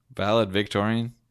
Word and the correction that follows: valedictorian: How do you say it? – The valedictorian.